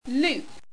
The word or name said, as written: lupe